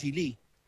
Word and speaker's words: Geely: La pronunciación correcta en China es Geely. Nosotros acá le decimos Geely o Geely, pero sí, normalmente es Geely.